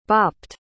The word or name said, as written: bopped